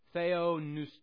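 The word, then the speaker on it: theonoustos (θεόπνευστος): P-N-E-U. Someone help me out. Do you know of any other words that might have actually start with that? New? We would pronounce it new, but theonoustos.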